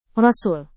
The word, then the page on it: rasul: Indonesian